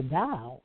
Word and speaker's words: Tao: Wayne Dyer, you'll see the T-A-O, which is pronounced the Dow, okay? The Dow to Chin, C-H-I-N.